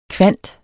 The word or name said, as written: kvant